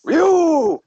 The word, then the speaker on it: Ryu: Or the way it's supposed to be pronounced, Ryu. – Ryu.